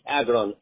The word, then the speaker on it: Agron: Now, Gwenny, your first name, you spell it G-O-N, like Nancy, I like ice cream. – No, my first name is Agron, A-G-R-O-N. – A-G-R-O-N, Agron. Okay. And what's your last name? – Stay good. Naziri. N-E-Z-I-R-I.